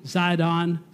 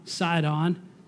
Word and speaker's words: Sidon: Now, Sidon is sometimes spelled with an S. Sometimes in history books it's spelled with a Z. Zidon, Sidon.